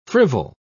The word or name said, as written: frivol